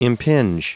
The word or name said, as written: impinge